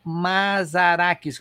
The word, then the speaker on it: Mazarakis: Quem é Ellen Mazarakis? Eu liguei para você para justamente dizer se eu estou pronunciando certo, né? – Pergunta. O nome, mas, né? Fala do jeito que se escreve.